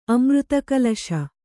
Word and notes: ಅಮೃತಕಳಶ: amřtakaḷaśa

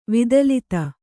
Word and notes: ವಿದಲಿತ: vidalita

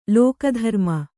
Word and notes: ಲೋಕಧರ್ಮ: Kannada

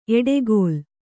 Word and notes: ಎಡೆಗೋಲ್: eḍegōl

